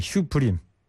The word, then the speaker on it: Supreme: SUPREME, 슈프림.